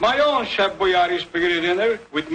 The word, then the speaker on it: Boyardee: But when you listen to this very first commercial for the brand, you'll realize the correct pronunciation was actually Chef Boyardee.